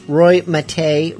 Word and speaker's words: Roy Mate: Roy Mate rhymes with pate, kind of.